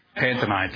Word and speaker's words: pantomime: was only acting a part in a heartless panatome. Pantomi. Anybody pronounce that one? Pantomime, thank you.